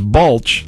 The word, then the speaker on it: Balch: And she asked us to make sure that she was pronouncing it right, that it was Balch. And we said, oh, yeah, that's right.